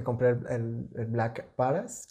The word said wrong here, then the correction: Black Parade: Ah, ¿Cómo, se llama? Black Parade.